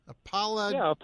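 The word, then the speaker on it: Apologetix: Apologetes? How do you say this? – Apologetes. – Okay. – Apologetes. – Well, you know, yes. – It's spelled APOLOG and then AD and S.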